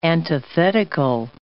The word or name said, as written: antithetical